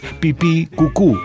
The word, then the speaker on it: Pipicucu: El Facebook es pipicucu, así nomás, pipí con acento en la segunda I, cucú con acento en la segunda U, Pipicucu.